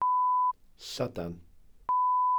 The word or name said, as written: llydan